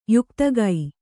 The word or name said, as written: ಯೌಕ್ತಿಕ